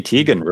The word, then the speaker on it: Teigen: Yeah, Christy Teigen really wants Animal Crossing on her Switch.